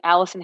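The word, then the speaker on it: Allycin: So you can find me at Allison Hicks everywhere. But here's the kicker. My name is spelled absolutely wild. So it's A-L-L-Y-C-I-N and then just normal Hicks, H-I-C-K-S. So that's Allison Hicks on Instagram.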